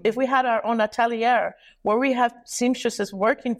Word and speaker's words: atelier: Atelier.